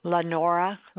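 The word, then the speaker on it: Lanora: Lenora Lindsay.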